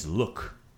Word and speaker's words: luk: The word for onion in proper Croatian is luk. – Luk. – Right? Luk. But in my family, maybe Dalmatian coast, you pronounce it kapula.